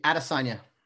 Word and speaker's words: Adesanya: How do you pronounce the name? Asanya? – Yeah, Adesanya. Adesanya. Israel Adesanya.